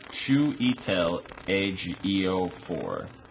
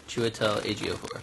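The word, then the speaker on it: Chiwetel Ejiofor: His name is pronounced Chuitel Ejiofor. – Chuitel Ejiofor.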